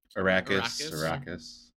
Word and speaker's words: Arrakis: For Dune, Arrakis. – What's the name of the planet? – Arrakis.